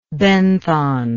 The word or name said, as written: benthon